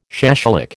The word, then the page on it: shashlik: American English